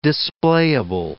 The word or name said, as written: displayable